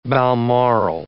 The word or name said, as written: balmoral